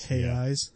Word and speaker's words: Reais: What's their currency called? – Reais. It's like... R E A I S, I think it's spelled. – R E A I S. – R E A I S. – Hey eyes. That's like a song. Well, like R is like an H down there.